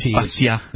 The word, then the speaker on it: Bastiat: So first off, before we get corrected again, I'd like to say that it's in all likelihood that Friedrich Bastiat's name is pronounced without... – You don't put the T sound in? – Bastiat. Yeah, one of those French things. You just drop off all the letters in the end.